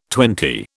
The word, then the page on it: twenty: British English